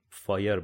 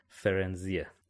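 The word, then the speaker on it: Firenze: یه جوریه که آدم احساس میکنه باید فایر بخونه، ولی در اصل اسمش فرنزیه که شما تو کتاب صوتی هم گوش بدی همین فرنزی خونده میشه.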